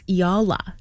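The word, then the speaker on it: Eala: I was corrected today. It's Alex Iala.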